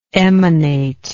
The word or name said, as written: emanatively